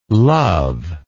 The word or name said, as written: luv